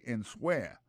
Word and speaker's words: answer: Ça sortit de verbe to answer, qui veut dire répondre, and so I écrit comme ça, A-N-S-W-E-R. Alors, sous ta, pour un temps, pour prononcer, il y a tout le monde qui a dit answer. C'est pas comme ça, on dit, c'est answer.